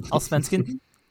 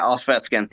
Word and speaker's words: Allsvenskan: I can't pronounce the league name, but Allsvenskan. – Yeah, Allsvenskan. Yep, that's it.